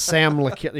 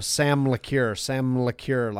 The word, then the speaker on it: Sam LeCure: But it's Sam LeCure, you know, Sam LeCure, like the M rolls into the last one.